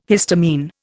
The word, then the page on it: histamine: Indian